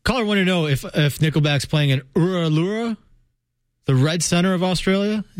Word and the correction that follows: Uluru: Am I not saying it right? – Oh, this is great. – How do you say it? What do you say? – Say it again. – Uralura? Uralura? How do you say it? – Uluru. – Uluru. Okay. I'm just repeating what I was told. – Yeah, it's Uluru.